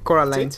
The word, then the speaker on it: Coraline: ¿Coraline? – Coraline, sí.